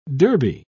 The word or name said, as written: derby